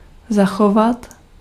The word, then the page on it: garder: France (Paris); [ɡaʁ.de]